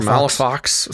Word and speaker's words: Malifaux: Malifaux sounds fine to me. – Okay. All right. – Malifaux? Would say maybe Malifaux. – I was wondering if this is a silent X thing there.